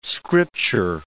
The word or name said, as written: scripture